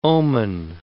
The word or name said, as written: omen